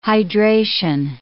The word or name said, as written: hydration